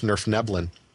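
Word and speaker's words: Svirfneblin: And I believe that their actual name is pronounced Nerfneblin. I think I actually pronounced that right.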